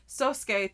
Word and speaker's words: Sosuke: And another thing that made me go like, what the heck is, so she brings Sasuke to, am I saying his name right? – Sosuke to school.